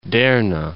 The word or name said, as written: Derna